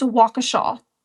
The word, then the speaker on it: Waukesha: So, when I said the name of the town last episode, I think I said, like, Waukesha or something like that. That is not the name of the town. It's Waukesha.